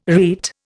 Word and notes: rete: Indian

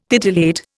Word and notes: titillate: Indian

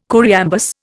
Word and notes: choriambus: Indian

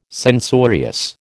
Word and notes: censorious: American English

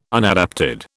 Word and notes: unadapted: British English

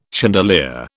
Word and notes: chandelier: British English

